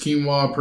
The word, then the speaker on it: quinoa: Quinoa, pronounced quinoa, has recently received...